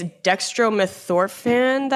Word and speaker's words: dextromethorphan: Did you say it's dextromethorphan that's a cough suppressant? – Yes. – Did I say that right? – Yes.